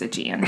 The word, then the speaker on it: Aegean: I thought it was Aegean, but then I looked it up on YouTube, and the pronouncing people said it was Aegean. I looked up two different ones.